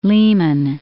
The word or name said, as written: Lehman